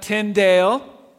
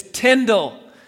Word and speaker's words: Tyndale: My name is not Tyndale, it's Tyndall. Rhymes with spindle.